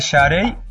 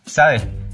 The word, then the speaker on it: Sade: Se trata de Sade, o como preferimos pronunciarlo en español, Sade.